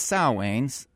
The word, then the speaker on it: Samhain: The Celtic festival of Samhain, pronounced Samhain, meant summer's end.